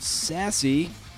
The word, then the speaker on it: SASE: She's the founder of Sassy. It's spelled S-A-S-E, like self-addressed stamped envelope.